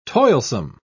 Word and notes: toilsome: British English

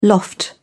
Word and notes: loft: British English

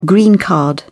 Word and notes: green card: British English

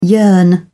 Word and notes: yearn: English; British English

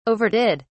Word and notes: overdid: British English

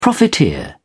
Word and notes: profiteer: British English

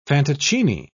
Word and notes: fantoccini: British English

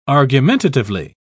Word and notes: argumentatively: British English

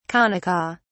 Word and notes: kanaka: British English

